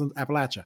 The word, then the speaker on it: Appalachia: For all my friends, for all my friends who are from that area, I'm going to pronounce it the way it's supposed to be pronounced, Appalachia.